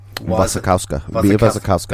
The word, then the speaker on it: Wasikowska: Wasikowska.